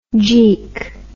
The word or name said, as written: jeek